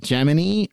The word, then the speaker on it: Gemini: The word is Gemini, pronounced like eye and eyeball. And the astronauts took to pronouncing it Gemini, most notably Gus Grissom, publicly. And it actually started a debate at the time, like, is it Gemini or is it Gemini? And the public affairs officer at NASA came forward and said the program is officially called Gemini, like knee, K-N-E-E.